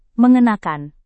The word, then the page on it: mengenakan: Indonesian